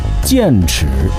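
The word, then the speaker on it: 僭侈: 这俩字念剑齿，用现在的话说就是越级享受高等待遇，外加奢侈浪费。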